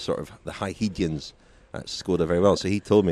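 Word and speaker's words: Škoda: Yes, thank you very much indeed to Skoda, as we determined last night was the correct pronunciation.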